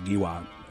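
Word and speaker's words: Giroir: I don't know how to exactly pronounce it. G-I-R-O-I-R. I think it's pronounced as Giwa. Brett Giwa.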